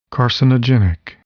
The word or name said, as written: carcinogenic